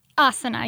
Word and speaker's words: Asana: So tell me about Asana Soul. And I always say Asana, but is Asana, right? – Asana, yes, like Shavasana.